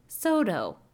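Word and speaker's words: Saulteaux: which has the craziest fucking spelling, and then I looked up the pronunciation, it was like, Soto.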